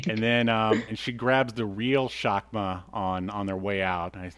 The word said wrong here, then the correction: Chakram: It's called Chakra. – Chakra. – C-H-A-K-R-A-M.